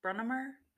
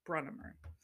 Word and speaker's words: Brunnemer: Brennemer. So the last name is spelled B-R-U-N-N-E-M-E-R. Brennemer.